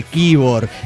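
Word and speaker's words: Quíbor: es toponomía indígena venezolana. – Bueno, pero suena. – Y se pronuncia como se escribe, Kibor.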